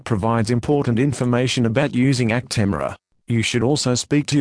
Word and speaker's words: Actemra: This is the consumer medicine information for Actemra, pronounced Actemra.